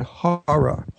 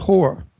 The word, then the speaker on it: horror: And I must teach how to say the word horror. – Horror. – He says whore. So he said he's a big whore fan.